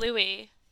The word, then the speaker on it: Louis: It is Louis. – It is Louis, and it's spelt the same way.